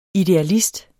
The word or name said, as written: idealist